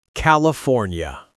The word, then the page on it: California: American English